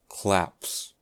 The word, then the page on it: claps: American English